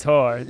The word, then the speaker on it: Hoare: Like, they didn't want to say whore. – They know, so it's whore.